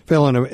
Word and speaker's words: Phelon: Phelon, am I pronouncing that right? – Yes, you are.